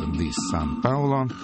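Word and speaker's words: The Sun Paulo: Ну, не как бразильский город, а «San» как слово «солнце» пишется. «The San Paulo».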